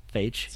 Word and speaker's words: Feige: Feige? – Feige, sounds good.